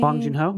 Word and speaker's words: Bong Joon-ho: Bong Joon-ho. – Bong Joon-ho.